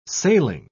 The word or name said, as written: sailing